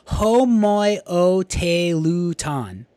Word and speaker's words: homoioteluton: And then there is another one that I wanted to mention, last one, and it's called homoioteluton, six-syllable word, yeah.